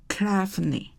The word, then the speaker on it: Clephane: C-E-L-I-S-I-A 哈，Charlesia。然后第三个字是 Clefany，哈，这个 C-L-E-P-H-A-N-E，哈，Clefany，哈，C-L-E-P-H-A-N-E，哈，那这个中文就翻把第三个字拿出来翻成克莱芬女士。